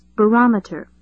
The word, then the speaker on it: barometer: That's spelled E-X-E-R-T-S. Let's pronounce the word. Repeat after me. Exerts. Again, exerts. Number two is barometer. B-A-R-O-M-E-T-E-R. Repeat, barometer. Notice the stress. Barometer. Number three is inflate. I-N-F-L-A-T-E. Repeat, inflate. Number four is volume. V-O-L-U-M-E.